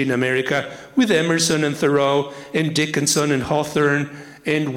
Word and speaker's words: Thoreau: Thoreau, now it's pronounced Thoreau, was very concerned that the founding fathers had committed the original sin.